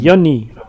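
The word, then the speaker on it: yoni: Tak, ale czyta się przez J bodajże. Joni. – Joni.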